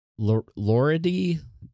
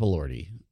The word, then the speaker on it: Elordi: Jacob Elordi from Frankenstein, who, fun fact, my wife cannot say his name correctly. She's like, Jacob Elordi? Like Jacob Elordi? It's Jacob Elordi.